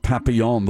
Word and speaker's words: papillon: Butterfly in French is pronounced papillon.